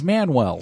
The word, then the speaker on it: Manuel: Yes, that's how it's spelt, yeah. Dummy, I'm but it's saying. Pronounced Manuel. Yeah. – Shut up. Shut up. – It's like he's a healthy dude. He's Manuel.